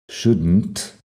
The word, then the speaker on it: shouldn't: También es un poco complicado pronunciarlo, pero shouldn't directamente de la D a la N a la T.